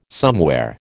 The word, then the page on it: somewhere: American English